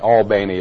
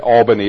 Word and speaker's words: Albany: Albany is how they pronounce it over there. Albany is how they pronounce it in New York.